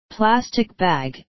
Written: plastic bag

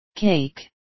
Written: cake

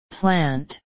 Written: plant

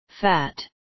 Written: fat

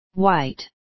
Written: white

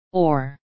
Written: or

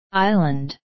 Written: island

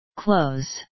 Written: close

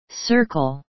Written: circle

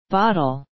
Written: bottle

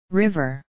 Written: river

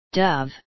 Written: dove

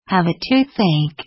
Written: have a toothache

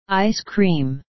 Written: ice cream

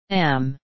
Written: am

